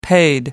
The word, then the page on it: paid: American English